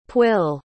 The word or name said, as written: pwyll